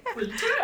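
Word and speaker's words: Pulter: So then you move from Anne Clifford into another woman, a poet, Hester Poulter. Am I saying that correctly? – I like the way you say it. Oh. I mean, I don't think anyone knows, so I think we can say Poulter. I quite like that.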